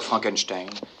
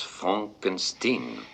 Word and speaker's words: Frankenstein: J'ai une question, docteur Frankenstein. – Docteur Frankenstein. – Je vous demande pardon. – Mon nom, monsieur, se prononce Frankenstein.